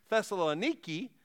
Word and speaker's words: Thessaloniki: Unlike some biblical cities that are ancient ruins, the city of Thessalonica, although it's pronounced today in modern-day Greece, Thessaloniki, the city still exists.